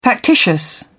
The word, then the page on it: factitious: British English